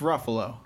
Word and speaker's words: Ruffalo: You guys need to start saying his name right. It's Ruffalo.